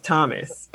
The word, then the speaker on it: Tomas: Spelled Tomas, but pronounced Thomas.